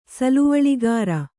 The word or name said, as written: ಸಲುವಳಿಗಾರ